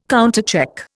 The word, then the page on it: countercheck: Indian